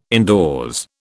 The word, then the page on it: indoors: British English